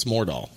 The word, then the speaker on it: Mordal: It sounds like there's an H in there, but there's not. It's Mordal.